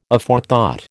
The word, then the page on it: Aforethought: American English